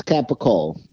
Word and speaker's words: capicola: Because the Italians, they announce it or pronounce it, however you say that word. They say capicola, okay? It's capicola.